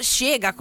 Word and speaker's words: Chega: Questa è la sua Shega, che si scrive C-H-E-G-A, ma essendo portoghese si pronuncia Shega, come insegna la mia amica Mari.